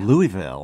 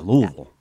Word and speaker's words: Louisville: Louisville. – And I said it correctly so that I didn't have to have marbles in my mouth with Louisville. – Yeah, Louisville. Yeah. Like with gravel, like Louisville.